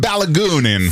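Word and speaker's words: Balogun: Eli Donald on soittanut mitä ilmeisimmin FIFAn johtoon tästä Yhdysvaltain Folarin Balu, miten tämä lausutaan? Pologuni. Folarin Pologuni. No niin, amerikkalainen. Folarin Pologuni. – Folarin Balagunin.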